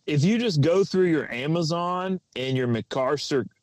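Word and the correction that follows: McMaster-Carr: how do you say that? That McMaster car. – McMaster car.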